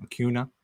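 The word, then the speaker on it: Acuña: Uh, I'm going to pronounce it wrong, Acuna. I believe it's correct.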